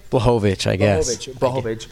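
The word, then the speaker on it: Błachowicz: And same as Israel Adesanya, I felt like when he went up and fought, Jakovich, did I say it right? – Blahovich.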